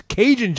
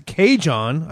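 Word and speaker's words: Kajan: You may know him as Cajun Johnson, but wants to be known as Cajon. I'm saying that right.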